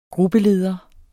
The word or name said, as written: gruppeleder